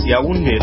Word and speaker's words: Yaoundé: How do you pronounce that? – Yes, Yaoundé. – All right. I did pretty good there.